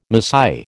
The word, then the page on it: Masai: American English